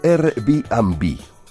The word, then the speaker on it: Airbnb: Lo pronunciamos como Airbnb. Ya saben, una nueva forma de hacer turismo en lo que se refiere a alojamientos.